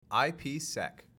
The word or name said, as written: IPsec